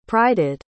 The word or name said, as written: prided